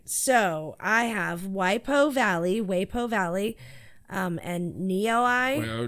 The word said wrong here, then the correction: Waipio: Waipio Valley, Niue Beach.